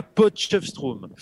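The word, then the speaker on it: Potchefstroom: à Potchefstrom.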